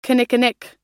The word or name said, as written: kinnikinnick